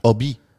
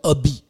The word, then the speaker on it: Obi: Obi. – Und der, die können ja das H nicht aussprechen. Ja. Ne? Die sagen ja statt ist stumm. – Statt Hobby sagen die ja Obi.